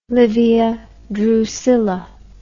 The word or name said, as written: Livia Drusilla